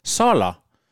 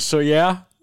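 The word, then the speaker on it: Sóller: der blev udtalt Soller. Og altså, nu har jeg ikke sådan hørt nogen lokale, men jeg er ret sikker på, at det skal udtales Soyer.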